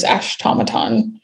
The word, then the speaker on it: Ashtomaton: I'm only on Instagram, which my name on there is Ashtomaton. – Perfect. – Like automaton. It's spelled like automaton, but with the first part of my name.